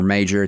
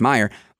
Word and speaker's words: Meijer: Now, on the show, she's got Representative Peter Meyer, and it's spelled Meijer. It's like the European Y sound, M-E-I-J-E-R. So it's not Meijer or Major, it's Meyer.